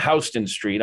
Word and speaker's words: Houston Street: It's spelled like Houston, but they call it Houston Street on in Lower Manhattan.